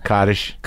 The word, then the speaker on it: Kaddish: Kaddish.